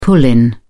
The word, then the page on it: pull-in: British English